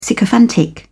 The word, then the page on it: sycophantic: British English